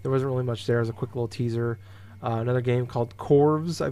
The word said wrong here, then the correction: Chorus: I believe is how you pronounce it. It's a space combat shooter. Is it Chorus? – Chorus, I've heard it called. – Chorus, really? – Yeah. – Looks like it's spelled Corvs, though, in the title. – Yeah, you're not the only one with a mistake, dude. It's their fancy way of saying Chorus.